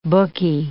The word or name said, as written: bookie